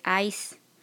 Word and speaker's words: ICE: Otra website importante es la del Departamento de Aduanas y Protección Fronteriza, que es ICE, así como se pronuncia de como hielo, ice.gov.